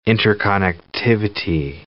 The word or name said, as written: interconnectivity